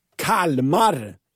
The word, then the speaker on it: Kalmar: Under senaste cykelturen så drog det igång en hyllningslåt till Kalmar i lurarna. Självklart med korrekt uttal. Kalma.